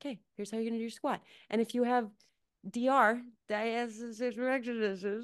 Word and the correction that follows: diastasis recti: Diastasis recti.